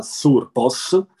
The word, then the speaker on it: Thurpos: Come hai detto tu, Turpos, o sarebbe meglio dire il TH in sardo si legge con una sorta di Z strisciata, Surpos,